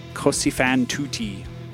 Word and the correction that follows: Così fan tutte: I'll just say Così fan tutte.